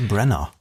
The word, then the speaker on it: Branagh: Spricht man den so aus, Kenneth Branagh? – Ich dachte Branagh. – Aber Branagh. Also geschrieben A-G-H am Ende, Branagh, wahrscheinlich.